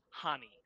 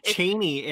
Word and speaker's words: Chani: her name is supposed to be pronounced Hani. Uh, the C is silent. I learned this. Um, wow. she's Ch- she's Cheney in the uh, in the audiobook.